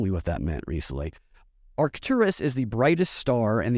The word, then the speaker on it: Arcturus: I don't even know how to pronounce this. Well, let's see. Let's see how Google pronounces it. Hold on 1 second here. Put it in there. – Arcturus.